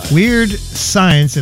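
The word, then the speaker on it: Weird Skyence: And last week, last Thursday, we had a rap duo called Weird Science, except they don't spell it S-C-I. They're too clever. They spell it S-K-Y. Weird Science. It looks like Skyence, but they say it's called Weird Science.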